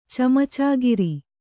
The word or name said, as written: ಚಮಚಾಗಿರಿ